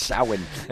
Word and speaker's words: Samhain: Samhain or Samhain, as Samhain. – It's properly pronounced. – Samhain.